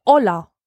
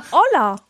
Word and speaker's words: Ola: Und ich heiße, frag mich nicht, wie es dazu gekommen ist, aber ich heiße in Polen Ola. Oida. Nein, Ola. – Ola. – Ola, so wie das spanische Ola. Also das Hallo, das spanische Hallo. Es wird genauso ausgesprochen wie das ja.